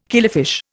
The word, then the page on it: killifish: Indian